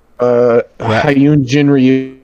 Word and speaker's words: Hyun Jin Ryu: Hyun Jin Ryu.